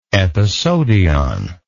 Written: epeisodion

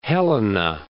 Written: Helena

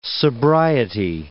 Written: sobriety

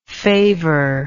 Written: favorless

